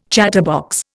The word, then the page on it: chatterbox: Indian